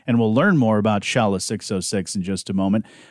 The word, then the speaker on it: Shala: We'll talk more about, is it Shayla 606? I forgot to ask about pronunciation. Shayla 606, right? – Shala 606.